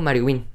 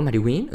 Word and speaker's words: MarioWin: me encuentran como MarioWin, nada más, si me encuentran. Eh, si no saben cómo se escribe MarioWin, es Mario Mario, así, mi nombre, Mario, W-I-N, así es mi bello apodo, Mariwin, uh, Mariwin el pro, Mariwin el crack, Mariwin el sexy, oh, sí, qué horror, qué feo sonó eso, pero...